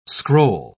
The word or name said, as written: scroll